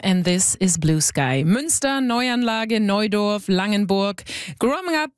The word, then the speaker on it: Münster: Suddenly people started talking about Munster or Neuanlage instead of Neuanlage.